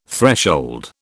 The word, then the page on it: threshold: British English